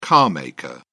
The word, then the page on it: carmaker: British English